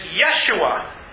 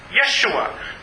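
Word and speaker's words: Yeshua (ישוע): So, in fact, is one of the Hebrew pronunciations of the word that we translate or we pronounce Jesus is Yeshua. Yeshua, which is also a kind of a condensed form of Joshua.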